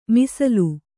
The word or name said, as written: ಮೀಸಲು